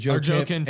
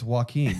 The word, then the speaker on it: Joaquin: And then I looked at him and I said, wait, is that really how you're pronouncing his name? And he goes, that's how you pronounce it. And I was like, it's Joaquin.